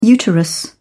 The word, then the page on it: uterus: British English